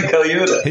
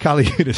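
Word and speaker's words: Colaiuta: All stars such as Keith Carlock, Anton Figg, and Vinnie Calo... I always... screw Vinny's last name. Can you pronounce Vinny? – Vinny Kaliuta. – Yeah, Vinny Kaliuta.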